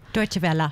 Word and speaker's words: Deutsche Welle: And I want to congratulate on her recent acceptance into a Deutsche Wells program, or Deutsche Welle. I think that's the right way to say it, isn't it? – Deutsche Welle.